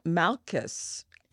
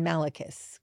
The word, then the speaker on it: Malchus: It's Malchus, M-A-L-C-H-U-S okay. – is his name. – And actually is often Malachus, because we see Malachus more often than Malchus.